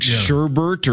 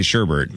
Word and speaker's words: sherbet: I think sherbet or sherbet is the other one. Ooh. We all just add an R and say sherbet.